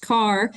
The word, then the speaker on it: Carr: It is pronounced Carr, to Grace's point earlier for W. Kerr Scott, but John H. Carr Dam and Reservoir is named after the Congressperson from North Carolina, John H. Carr, pronounced like you drive a car.